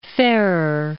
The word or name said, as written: Farrar